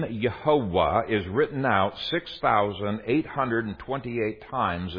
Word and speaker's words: Yehovah: Okay, it means praise Jehovah, or if you want probably a more accurate pronunciation, praise Yehovah. Praise Yehovah.